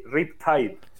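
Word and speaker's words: Riptide: Es RIP, R-I-P, espacio T-I-D-E. RIP Tide.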